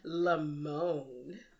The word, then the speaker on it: Lamon: It says here that you pronounce his name Lamone.